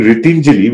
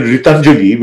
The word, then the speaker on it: Ritinjali: So they, instead of saying Ritinjali, which means helping hands, mm-hmm. – they pronounce it Ritanjali, which means empty hands.